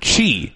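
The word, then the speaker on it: Qi: And this person's first name was spelled Q-I, right? – Right. – Which, if you've ever played Scrabble or have a little bit of inkling of the any of the Asian languages, it's actually pronounced Chi.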